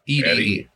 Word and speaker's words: Ede: And pronounce E-D-E. Ed? – Idy? – Eddie. – Eddie. – Eddie? – Eddie. – Eddie? – Yeah.